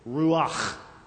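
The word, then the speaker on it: Ruach (רוּחַ): And if you say it right on the ending, it sounds like you're going to spit. But don't spit. It just sounds like it, all right? It goes like this. Ruach.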